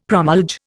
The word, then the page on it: promulge: Indian